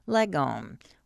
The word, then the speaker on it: lagom: This vision of restrained pleasure harmonizes with a related Swedish concept, lagom, which refers to a kind of moderation. Pronounced with a hard G,